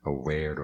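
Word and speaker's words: averā: V is always pronounced as a W. – O V sempre é pronunciado como se fosse um W. Tipo, like, aware or homie, the third line under metta, aware or homie.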